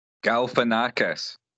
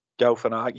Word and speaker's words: Galifianakis: Galifianakis. – Galifianakis, he's absolutely amazing in this.